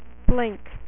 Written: Blink

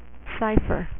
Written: Cypher

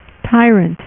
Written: tyrant

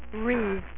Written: reavers

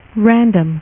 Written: random

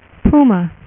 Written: puma

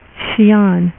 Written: xian

